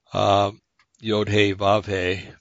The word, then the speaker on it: Yod Heh Vav Heh: So Yod Heh Vav Heh is how you say that. A lot of people turn it into Jehovah.